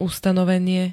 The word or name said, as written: ustanovenie